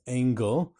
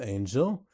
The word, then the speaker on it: Angel: I mentioned a rabbi who is Seattle origins, Rabbi Mark Angel, and I incorrectly pronounced it as Engel. So many were quick to correct it that it's pronounced Angel.